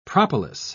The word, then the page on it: propolis: British English